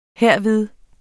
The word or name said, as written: herved